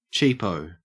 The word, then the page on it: cheapo: Australian English